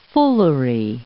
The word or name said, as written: foolery